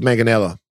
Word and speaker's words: Manganiello: Manganiello. – Joseph Manganiello.